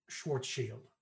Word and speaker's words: Schwarzschild: It's pronounced Schwarzschild. – Schwarzschild? – Shield. – Shield. Shield. – Means shield in German.